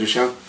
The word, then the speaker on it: Duchamp: Og der tænker jeg på, at du også nævnte, jeg mener det var, nu skal jeg lige se, jeg kan ikke udtale det rigtigt, Duchamp. – Ja, Marcel Duchamp.